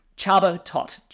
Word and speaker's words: Csaba Toth: So without further ado, I'm going to welcome to the show Csaba, Toth.